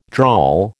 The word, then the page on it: drawl: American English